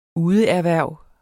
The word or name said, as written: udeerhverv